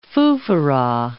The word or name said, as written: foofaraw